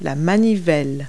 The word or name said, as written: crank